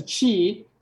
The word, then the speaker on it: qi: So the pathways created an energy flow we call qi, pronounced as qi, through the body that is responsible for overall health.